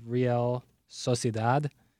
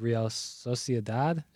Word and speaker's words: Real Sociedad: He likes Manchester United +115 in the Europa League against, I believe, Real Sociedad. – Excuse me? – Real, I'm butchering the name. It's Real Sociedad.